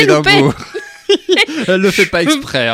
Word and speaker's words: Edinburgh: Alors, Glasgow et Edimbourg, que vous prononcerez à l'écossaise Edinburgh.